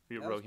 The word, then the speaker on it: Rohingya: And she spoke openly and defended the military's actions of the deadly campaign against, I'm going to butcher this name, the Rohingya. Rohingya, perfect.